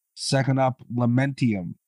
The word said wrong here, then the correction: Lamentum: Is that how you say it? I've never heard of that game. – Lamentum. – Okay, Lamentum.